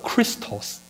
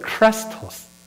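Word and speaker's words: Chrestos: 到了公元第 49年左右当时候呢出现了有一个市井之流吧，那个人的名字叫做 Crestos，那大家记住这个发音，因为这个发音它很容易被误读为基督那个词。那基督那个词希腊文叫做 Christos，一个叫做 Crestos，一个叫什么 Christos 啊。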